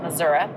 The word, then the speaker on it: Missouri: That's how they say it, Missouri.